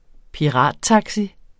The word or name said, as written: pirattaxi